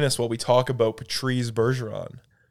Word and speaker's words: Patrice: You call him Patrice? – Patrice. – Patrice? – Patrice. That's how you say his name.